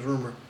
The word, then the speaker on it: RMR: The RMR, but they say his name is Rumor.